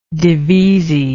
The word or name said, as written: divisi